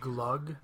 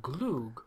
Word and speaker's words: glögg: It's not spelled like that, but I learned that it's called glögg, not glug, from a Swede.